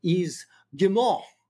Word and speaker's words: Yves Guillemot: In the strategy, according to their CEO, and I can't pronounce his name, but something like Yves Guillemot.